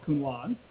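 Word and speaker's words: Counoise: If you go read the literature, if it has that E on the end, then you pronounce the vowels, and so it's Cunoise.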